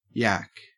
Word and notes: yack: Australian English

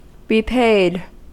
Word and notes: repaid: American English

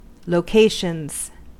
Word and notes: locations: American English

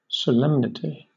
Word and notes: solemnity: British English

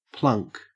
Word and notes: plunk: Australian English

